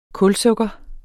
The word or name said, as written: kulsukker